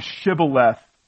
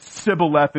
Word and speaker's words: Shibboleth: And because Jephthah seized the fords of the Jordan, they had a little test where they said to say the word Shibboleth, and the Ephraimites couldn't pronounce sh, and so they said Sibboleth instead.